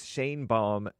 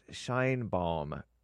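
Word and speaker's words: Sheinbaum: And speaking of that, I heard that it's Shane Baum, not Shine Baum, that Mexican Jewish female president woman.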